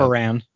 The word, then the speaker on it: Baran: But, and I mean, why does Baron, am I saying his name right? Is it Baron? What is his, I can't remember. – It's something like that. – Baran. – Oh, Baran. Yeah, Baran.